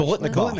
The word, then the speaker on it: Biletnikoff: Biletnikoff Award watch Biletnikoff. – List. – Biletnikoff.